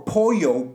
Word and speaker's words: pollo: And we see a mohawked revolutionary with the Hulk Hogan mustache and a flamethrower in his hand screaming, "For Pollo!" But Pollo in Spanish is spelled P-O-L-L-O. He's screaming, "For Pollo, P-O-Y-O. P-O-Y-O, Pollo, is that rooster that Chu and the chief of the island stole."